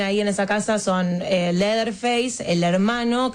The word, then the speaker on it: Leatherface: El Leatherface.